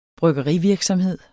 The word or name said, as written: bryggeri